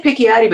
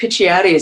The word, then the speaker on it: Picciotti: Actually, we could say Picciotti, but Picciotti is, I think, the right Italian way.